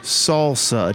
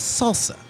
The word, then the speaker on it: salsa: How do you pronounce the word salsa? Do you pronounce it salsa or salsa? – With that weird Z that no one needs to add? Yeah, the silent and inexplicable Z? No, it's salsa.